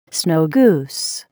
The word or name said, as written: snow goose